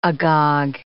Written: agog

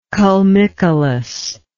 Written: culmicolous

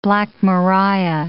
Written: Black Maria

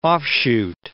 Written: offshoot

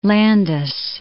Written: Landis

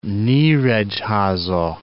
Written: Nyiregyhaza

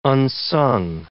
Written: unsung

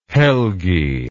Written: Helgi

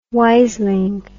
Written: wiseling

